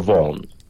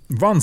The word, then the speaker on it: Vaughan: No czy się, ja usłyszałem jakby bardzo często tą wymowę jego nazwiska jako Vaughan. – Vaughan, a może. – Tak. Że to G i to H jest po prostu pełne jak Von. W Irlandii troszkę tak, no.